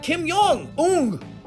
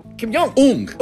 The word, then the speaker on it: Kim Jong Un: And Kim Jong. Ung. – I don't think you pronounce that like Kim Jong. – Kim Jong. – Ung.